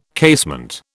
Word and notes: casement: British English

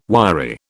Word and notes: wiry: British English

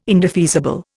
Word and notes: indefeasible: Indian

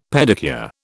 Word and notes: pedicure: British English

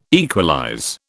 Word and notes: equalize: British English

